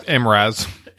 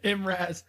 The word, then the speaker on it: Mraz: Jason Mraz? – Never heard it. – Hmm. – And it's pronounced Mraz. – Mraz.